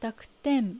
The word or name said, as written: Dakuten